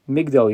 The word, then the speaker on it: Migdol: As an aside, in Harachaman on Shabbos, there's a change in how we pronounce one of the words. During the week, we say Magdil Yeshuos Malko, and on Shabbos we say Migdol Yeshuos Malko.